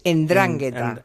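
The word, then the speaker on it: 'Ndrangheta: Vale, una ni siquiera la pronunciaba bien, es Endrangheta. En, endrangheta, sí.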